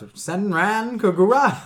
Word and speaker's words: Senran Kagura: Whole topic will be about Kenran Kagura. No, it's Senran Kagura.